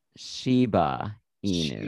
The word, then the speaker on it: Shiba Inu: I mispronounced the dog, because it's a similar dog breed too. – Yes. – And it's actually pronounced Shiba Inu. – Shiba Inu. – And I know this, I'm obsessed with them, mm-hmm. but my dumbass was like, it's a Shina Ibu.